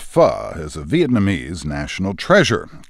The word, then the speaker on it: pho: It's spelled P-H-O, pho, but pronounced pho.